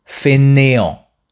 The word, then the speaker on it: fainéant: Écoutez bien la différence de prononciation entre ces deux adjectifs. Feignant, fainéant. Feignant, fainéant.